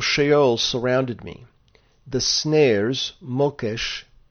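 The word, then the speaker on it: mokesh (מוקש): And that word in Hebrew, translated snare, is mokesh.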